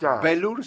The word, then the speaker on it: velour: Velour se pronuncia así, pato.